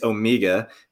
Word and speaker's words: Omega: Always, you know, all the Omega references, and that's how you say it, right? It's Omega because that's how they say it in the James Bond movies.